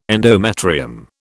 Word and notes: endometrium: British English

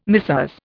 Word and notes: Messieurs: Indian

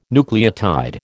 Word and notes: nucleotide: American English

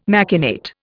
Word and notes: machinate: Indian

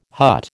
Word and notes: hut: American English

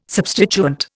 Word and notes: substituent: Indian

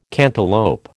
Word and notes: cantaloup: American English

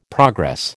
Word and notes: progress: American English

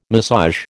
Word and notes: massage: American English

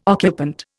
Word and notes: occupant: Indian

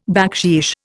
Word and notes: baksheesh: Indian

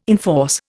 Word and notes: enforce: Indian

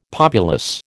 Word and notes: populous: American English

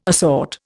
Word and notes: assort: Indian

